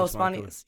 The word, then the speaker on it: spanakopita: What about spanakopita? – Oh, spanakopita. Yeah, spanakopita.